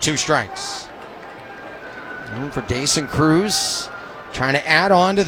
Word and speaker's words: Croes: Alan Cruz spells his last name C-R-O-E-S. But it is pronounced Cruz. And I actually have it spelled in my book, C-R-U-Z. Just to be sure we get it right.